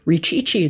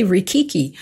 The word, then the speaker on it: Richichi: We spell it W-A-N-G. – Oh, good. – Yeah. – It's kind of like my name, Richichi. It's really Rikiki.